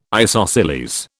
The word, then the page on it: isosceles: British English